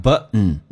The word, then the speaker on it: button: Button 我们都明白，指的是按钮，但 button 能不能听懂？美语当中 T N 组合 T 可以不用发音，但气流要阻塞一下，变成 button。